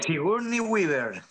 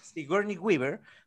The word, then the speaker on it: Sigourney Weaver: Sigourney Weaver, la que hizo Alien. Sigourney Weaver. – Sigourney Weaver.